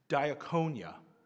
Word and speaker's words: diakonia: It's diakonia. There's a couple different pronunciations. I'm going with that one.